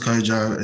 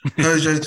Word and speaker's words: Kwadwo: My name is Kojo. It's spelled really weirdly. I'm spelled K-W-A-D-W-O. So, often people see my name and try and pronounce it their best and get it totally wrong. But yes, Kojo, 29, approaching 30.